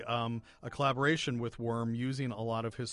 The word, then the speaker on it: Wurm: And I know that sounds like a strange name, but his name is Erwin Worm, and his last name is spelled W-U-R-M.